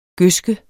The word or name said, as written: gødske